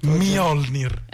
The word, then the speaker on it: Mjolnir: Mjolnir, come Mjolnir. Il martello di Thor.